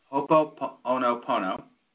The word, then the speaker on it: Ho'oponopono: Ho'oponopono is incredibly natural, first of all.